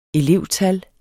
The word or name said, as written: elevtal